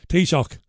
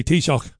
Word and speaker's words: Taoiseach: The Irish Taoiseach, I used to get hammered years ago when I was on Irish radio for not pronouncing Taoiseach properly. Taoiseach. We used to say Taoiseach. You can't say that. You've got to say Taoiseach.